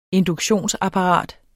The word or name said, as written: induktionsapparat